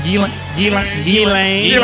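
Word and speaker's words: Ghislaine: Am I saying it right? – G-Lane.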